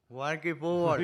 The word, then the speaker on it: Walking Football: Al walking fútbol. – Walking fútbol. – Walking fútbol. A ver cómo pronuncias inglés. Walking fútbol. – Walking Football. – Bien.